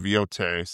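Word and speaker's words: Yōtei: And I think it's actually pronounced Ghost of Yote, so I just sound stupid.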